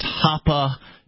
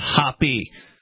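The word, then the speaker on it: Hoppe: Blockchain Bellwether says it's pronounced Hoppa, not Hoppy. So they must be a fan. They must be a fan of... It's like Hoppa, like get to the Hoppa.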